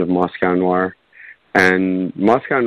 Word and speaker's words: Noir: Noir. Noir.